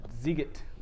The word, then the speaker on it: Sziget: Can you pronounce it? The Shibizbit? – The Sziget. – Thank you.